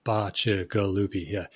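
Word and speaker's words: Bacigalupi: I'm saying it wrong. Bacha Galupi. – Bacha Galupi. – Galupi. That's how you pronounce it. Bacha Galupi.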